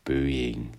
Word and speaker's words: Boeing: I'm quite sure that not every American is really able to pronounce his name correctly. So say after me, please. Boeing.